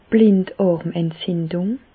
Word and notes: d'r Blindàrmentzindùng: Bas-Rhin; Reichshoffen

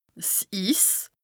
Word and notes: 's Iss: Bas-Rhin; Strasbourg

